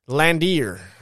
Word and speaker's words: Landwehr: I would give him Nate Landwehr, I believe is how it's pronounced. L-A-N-D-W-E-H-R.